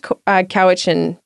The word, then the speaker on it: Quw'utsun': And there was a booth there by this little company called, it's pronounced Cowichan Made. It's spelled, it's not anglicized the way that it's spelled. So it's Quw'Utsun'.